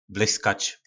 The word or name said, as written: blykac